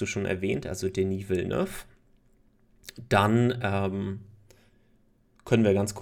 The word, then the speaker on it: Villeneuve: Und zwar ist das Dune von Denis Villeneuve oder Denis Villeneuve, je nachdem, wie französisch man ihn ausspricht. – Genau, ich glaube, er ist sogar Franzose, also mhm. – Denis Villeneuve würde er ausgesprochen werden. Ähm, ja, Dune oder Dune, da gibt es interessanterweise diese zwei unterschiedlichen Aussprachen, äh, also Aussprachen des Filmtitels.